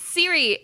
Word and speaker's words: Ciri: It's Ciri. It's Ciri, just like your phone.